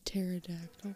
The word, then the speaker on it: pterodactyl: She's called a pterodactyl. – No. – Pterodactyl. – Yeah, that's what I said. – The P is silent.